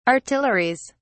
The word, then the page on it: artilleries: British English